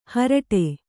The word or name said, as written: ಹರಿಟೆ